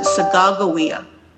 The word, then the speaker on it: Sacagawea: We always say Sacagawea, but I was corrected by the Hidatsa Mandan friend of mine, and they pronounce it Sacagawea.